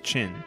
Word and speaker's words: Qin: This new capital was first named Jiangsu, but in 316 BC, the state of Ba was conquered by the state of Qin, which I believe is the one... that you would pronounce Chin, and hence China.